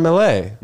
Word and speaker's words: Millea: How do you say it? – Sean Millea. – Millea, yeah. – But I wouldn't say Sean Miller. – No, people say Miller. They say Millea.